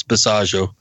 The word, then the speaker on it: Basiago: Basiago. It rhymes with Chicago. It's Basiago.